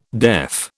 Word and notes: deaf: British English

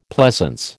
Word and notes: pleasance: American English

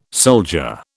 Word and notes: soldier: British English

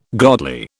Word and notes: godly: British English

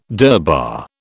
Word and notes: durbar: British English

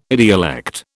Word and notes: idiolect: British English

